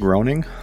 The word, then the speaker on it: Groening: Matt Groening, who made The Simpsons. – Yeah. – I Definitely didn't think that's how you pronounced it, but yeah, okay.